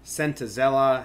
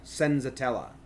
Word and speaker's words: Senzatela: Sentazella hasn't seen. Uh, I think like Voit and someone else have hits for Senta, Senza. I've been saying it wrong. Senzatela, which is probably still saying it wrong.